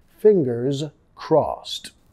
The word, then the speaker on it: fingers crossed: Fingers, sonido de Z al final, fingers crossed. Crossed ed pronunciado como una T.